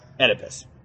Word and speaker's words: Oedipus: Right, Oedipus. Spelled with an O.